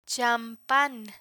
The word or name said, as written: champan